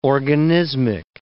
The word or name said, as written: organismic